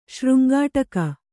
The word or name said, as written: ಶೃಂಗಾಟಕ